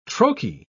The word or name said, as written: troche